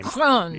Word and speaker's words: Macron: I know how to overpronounce his name. – Macron. Yeah. There's a lot of R something. Yeah. It's like you're sort of sneezing or throwing up together.